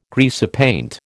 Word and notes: greasepaint: American English

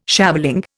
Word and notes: shaveling: Indian

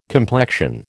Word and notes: complexion: American English

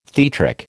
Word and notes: theatric: American English

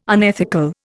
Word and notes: unethical: Indian

